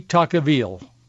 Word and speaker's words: Tocqueville: Tocqueville. His name is pronounced in a dozen different ways, but that is the most common way to pronounce his name.